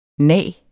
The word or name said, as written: nag